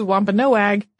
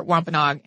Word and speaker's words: Wampanoag: Before anyone writes in, I have heard humans pronounce these as Wampanoag and Aquinnah. However, the far more common pronunciation that I found, including people that I checked with directly, were Wampanoag and Aquinnah.